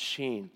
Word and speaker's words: shin: That is a sheen, spelled S-H-I-N, pronounced sheen.